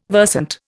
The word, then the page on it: versant: Indian